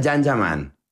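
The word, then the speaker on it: Can Yaman: Se escribe Jan Yaman, se pronuncia Jan Yaman.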